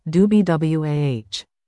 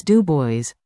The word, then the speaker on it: Du Bois: I even prided myself for pronouncing his name correctly, the French way, Du Bois. But my grandfather interjected while I was talking and said, "It's Du Bois."